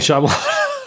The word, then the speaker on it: Shyamalan: M Night Shyamalan.